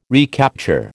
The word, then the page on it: recapture: American English